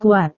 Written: kuat

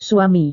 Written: suami

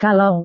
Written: kalau

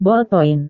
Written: bolpoin

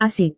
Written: asik

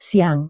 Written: siang

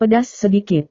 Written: pedas sedikit

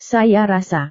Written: saya rasa

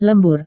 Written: lembur

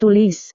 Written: tulis

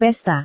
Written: pesta

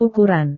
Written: ukuran

